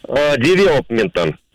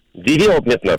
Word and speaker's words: девелопмента: Данное слово пришло к нам из английского и сохранило ударение на второй слог. Поем тому правильно говорить еще раз. Как? – Девелопмента.